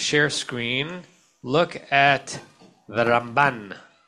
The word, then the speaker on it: Ramban: So he would say that the way you distinguish it is that which syllable you stress, it's the Rambam and the Ramban. So this is the Ramban.